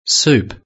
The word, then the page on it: soup: British English